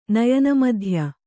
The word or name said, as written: ನಯನಮಧ್ಯ